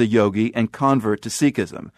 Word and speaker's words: Sikhism: Before I go any further, I know there are two pronunciations of the faith, Sikhism and Sikhism. And for the purposes of this conversation, we're going with Sikh and Sikhism.